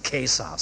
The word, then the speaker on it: Caesar: jeder, der heute Latein lernt, weiß wieder, dass man Caesar sagen muss. Wir haben damals drüber gelacht. Aber heute heißt das wieder der Caesar. Das C muss wie K ausgesprochen werden.